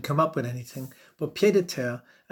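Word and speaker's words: pied-à-terre: And it's the French pronunciation is pied de terre, pied de terre.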